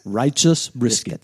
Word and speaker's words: Righteous Brisket: Y este primero, que hemos escuchado después de la sintonía del programa, vamos a ver si lo pronuncio bien, Righteous Brisket. – Righteous Brisket, sí.